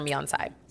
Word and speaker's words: Miansai: These are all, both of the ones, both of all my bracelets are Miansai. – Miansai, how do you spell that? – M-I-A-N-S-A-I.